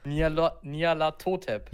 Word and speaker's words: Nyarlathotep: Totep. Die Betonung ist auf das To. – Nialatotep.